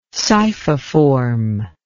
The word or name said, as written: scyphiform